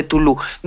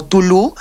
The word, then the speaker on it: Cthulhu: Nosotros aquí en Hexen hemos decidido desde hace mucho tiempo pronunciarlo como Tulu, pero como veremos, hay distintas pronunciaciones que son aceptadas y que las puede adecuar cada uno personalmente según lo quiera. Nosotros siempre lo hemos llamado Tulu, así lo conservaremos, aunque las personas que nos están escuchando van a poder elegir su propia pronunciación.